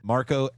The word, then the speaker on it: Marko: It's Marko, M-A-R-K-O dot Djurdjic, D-J-U-R-D-J-I-C.